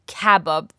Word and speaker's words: kebab: And note here, the pronunciation is a little bit different than the English kebab. So it's kebab. The emphasis is on the first syllable.